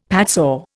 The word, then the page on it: padsaw: Indian